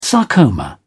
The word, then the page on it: sarcoma: British English